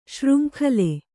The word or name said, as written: ಶೃಂಖಲೆ